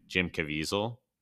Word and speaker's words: Caviezel: But Jim Caviezel.